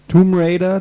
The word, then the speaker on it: Tomb Raider: Tomb Raider, das B wird nicht ausgesprochen und.